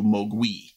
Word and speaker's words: Mogwai: So in Cantonese, Mogwai means devil, demon, or gremlin. In Mandarin, the pronunciation is more akin to Mogui.